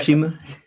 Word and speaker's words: Tsushima: Tsushima. Tsushima. Yeah. Okay, so the T is silent.